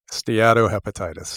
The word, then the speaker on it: steatohepatitis: This is a very serious problem, non-alcoholic, pronounce it for me, Steatohepatitis.